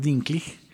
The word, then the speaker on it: Dinklage: No, yo le voy a decir Dinklage toda la vida, pero vamos a intentar. – vamos, a intentar mirar que la, pronunciación supuestamente es Dinkley.